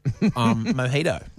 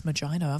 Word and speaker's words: mojito: Yeah, and welcome aboard the good ship, mojito. – Mm. – Yes. – I thought it was magino. I've been saying it wrong. – You always embarrass us by ordering magitos.